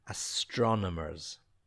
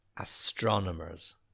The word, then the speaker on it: astronomers: See where we put the stress. Astronomers. Astronomers.